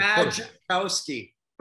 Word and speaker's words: Majkowski: Majkowski.